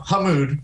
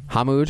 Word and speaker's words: Hamood: No, Hamood. Hamood.